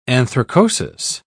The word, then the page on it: anthracosis: British English